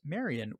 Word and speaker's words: Marion: Her name is Marion, which conveniently rhymes with librarian.